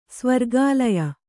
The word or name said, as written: ಸ್ವರ್ಗಾಲಯ